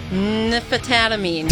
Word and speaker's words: Nymphamphetamine: Nymphamphetamine. Nymphamphetamine. – That's nymphamphetamine. – Nymphamphetamine. – He literally says it in the song. Nymphamphetamine. – Yeah, thank you. – One more time. Nymphamphetamine.